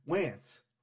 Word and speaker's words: Wance: Yes, as Gooby puts it, Wance rhymes with pants.